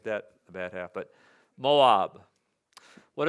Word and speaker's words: Moab: Why is he mispronouncing Moab? Did anybody think that for a moment? Moab? Maybe you thought, well, maybe that's the correct Hebrew pronunciation, and, you know, maybe I was wrong. And it might be. But, you know, typically we say Moab.